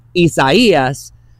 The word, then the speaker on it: Isaias: just as it sounds out because it's in Spanish, Isaias is going to be one that we're going to have to say a lot over this next week.